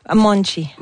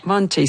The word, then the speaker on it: Monte: Monte, on dit Monte. – Monchi. – Monchi, ça s'écrit M-O-N-T-E pour les intéressés.